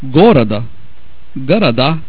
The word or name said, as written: goroda